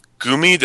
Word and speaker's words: Gumi: and it will feature songs created with Megapoid and performed by Gumi, did I say that right? – Yes, you did.